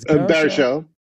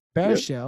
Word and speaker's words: Baruchel: Is Baruchel. – Baruchel.